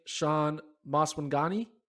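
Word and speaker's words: Shaun Maswanganyi: Saw the South African athlete, Sean Maswangani. Did I say his name right? I think I did. – You did it.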